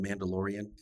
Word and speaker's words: Mandalorian: Am I right that the voice of the Mandalorian, am I saying that right? – Yes.